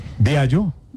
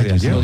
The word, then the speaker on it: Diageo: Diageo. No se dice Diageo.